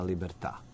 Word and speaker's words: Libertà: to je tema Libertà. Ako sam dobro akcentovao, da, ja Libertà, da.